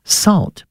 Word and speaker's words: Sowt: It's called Salt, but it's spelled S-O-W-T, and it means voice or sound your voice.